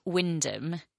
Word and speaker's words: Wymondham: As I discovered a few weeks ago when talking about Wyndham in Norfolk, which is spelt Wymondham, you can't trust Norfolk about pronunciation.